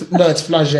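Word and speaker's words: Flagey: 5th, we're in Brussels at Flaggy. Am I saying it right? Flagge. – I've got no idea. – Yeah, it's Flagge. It's like, it's very Frenchy.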